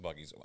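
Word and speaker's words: Buc-ee's: I've been to Florida from here, and I don't remember passing Busey. – Recently, in like the last year. – I like. Listen, before anybody comments and says, "Y'all, it's pronounced buggies."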